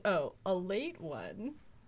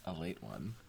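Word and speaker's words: Ale-8-One: Known colloquially as ale ate, pronounced as, a late one. – Oh, a late one.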